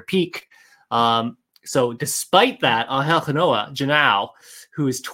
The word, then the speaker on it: Genao: And I think my first pick, so this is sort of RoboScout intriguing names, is Cleveland Guardians shortstop at low A, Angel Genoa, G-E-N-A-O. I'm going to pronounce it Genoa. He is just it's Genao. – Genao. I prefer Genoa. It's Angel Genao.